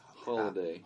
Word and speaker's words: Halliday: Halliday? – Holiday. – Holiday. – Holiday. – You say Halliday because it's spelled that way. – Yeah. – But then he's like, you don't say Halway.